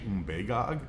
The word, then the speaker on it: Umbagog: It's actually Umbagog, but you don't run into a lot of Abenaki Indians to correct you on your pronunciation, and we bastardized it and called it Umbagog for so long, it just has now stuck as Umbagog. If you find a local, like, you know it's Umbagog, right?